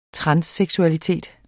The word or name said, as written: transseksualitet